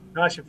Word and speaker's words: Takashi: Did I say his name right? – Takashi from Anatomia. That is correct.